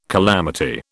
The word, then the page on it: calamity: British English